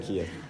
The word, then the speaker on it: chiasm: Chiasm or chiasm? – Based on the Greek letter chi, so it's technically chiasm.